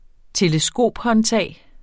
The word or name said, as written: teleskophåndtag